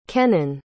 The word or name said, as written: kennan